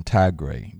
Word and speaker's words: Tigray: That's spelled T-I-G-R-A-Y. Yeah, Tigray. There's a, you know, important kind of thing that's been going on in Tigray.